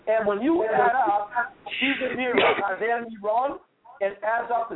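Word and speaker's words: Chazer Niron (קיסר נרון): If you want to say his name in Hebrew, Phil, it would be Chazer Niron.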